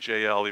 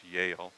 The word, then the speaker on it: Jael: I'm going to say Jael, even though it's pronounced Yael.